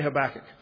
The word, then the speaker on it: Habakkuk: Some people pronounce it with the proper Hebrew, but I'm I'm from the country. And so I say Habakkuk. It rhymes with tobacco.